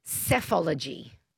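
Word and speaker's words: psephology: It's yeah, and it's pronounced cephology.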